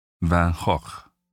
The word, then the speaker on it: Van Gogh: Though the family name is commonly pronounced Van Gogh in the UK and Van Gogh by Americans, in its original Dutch it's Van Gogh.